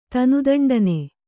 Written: ತನುದಂಡನೆ

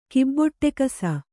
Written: ಕಿಬ್ಬೊಟ್ಟೆಕಸ

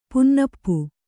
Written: ಪುಂನಪ್ಪು

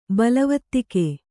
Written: ಬಲವತ್ತಿಕೆ

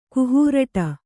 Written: ಕುಹೂರಟ

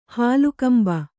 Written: ಹಾಲುಕಂಬ

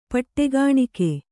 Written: ಪಟ್ಟೆಗಾಣಿಕೆ

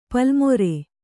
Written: ಪಲ್ಲುಮೊರೆ

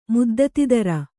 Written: ಮುದ್ದತಿದರ

